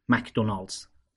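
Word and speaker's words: McDonald's: شرکتی که در این بخش می‌خوایم بهش بپردازیم مجموعه رستوران های مکدونالدز هست، یا اونطور که در فارسی اغلب تلفظ میشه مکدونالدز.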